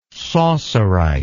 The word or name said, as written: saussurite